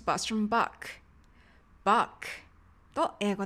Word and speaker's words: Bach: バッハさんのお名前は英語ではバックと最後 K で発音されます。ネイティブの方の発音を聞いてみましょう。 My favorite composer is Johann Sebastian Bach.